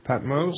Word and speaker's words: Patmos: That's how they say it. Patmos.